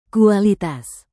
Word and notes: kualitas: Indonesian

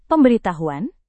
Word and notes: pemberitahuan: Indonesian

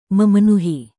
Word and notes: memenuhi: Indonesian

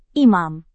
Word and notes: imam: Indonesian

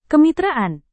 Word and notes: kemitraan: indonesian

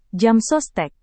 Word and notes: jamsostek: Indonesian

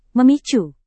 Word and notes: memicu: Indonesian

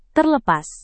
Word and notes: terlepas: indonesian

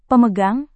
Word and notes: pemegang: Indonesian

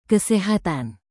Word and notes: kesehatan: Indonesian

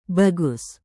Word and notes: bagus: indonesian

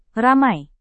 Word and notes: ramai: indonesian

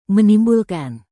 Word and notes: menimbulkan: Indonesian

